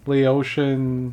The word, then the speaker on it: Laotian: I mean, labels it as a Laotian. – How you say it? – Laotian.